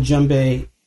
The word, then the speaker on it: djembe: You set these little djembe. Did I say that right? Djembe drums. – That's right. Yeah.